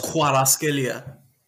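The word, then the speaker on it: Kvaratskhelia: Because apart from Saka and Vinny, and like maybe I'm not gonna try and pronounce that brother's name, but Maraskelia. – You know who I'm talking about? Yeah. – It's pronounced Kwaraskelia.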